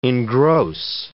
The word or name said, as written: engross